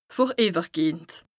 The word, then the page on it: vorìwwergehend: Bas-Rhin; Strasbourg